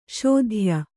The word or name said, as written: ಶೋಧ್ಯ